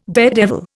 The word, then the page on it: daredevil: Indian